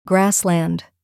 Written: grassland

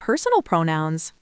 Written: personal pronouns

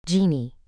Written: genie

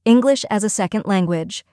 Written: English as a second language

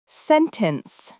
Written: sentence

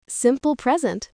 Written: simple present

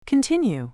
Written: continue

